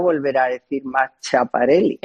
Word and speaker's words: Schiaparelli: No se dice Schiaparelli. – Perdón. – Schiaparelli. Ay, pobre Laurita, que no sabe italiano.